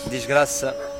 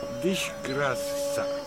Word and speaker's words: Disgrasa: How do you actually pronounce it? – Disgrasa. – Disgrasa. – Yeah, exactly, which means disgrace in Portuguese.